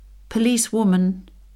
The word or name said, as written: policewoman